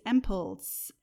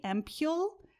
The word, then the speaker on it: ampoule: Next category is ampoules. I used to say ampoule, but I actually looked up the pronunciation and apparently it's ampoule.